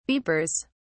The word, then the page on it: beepers: British English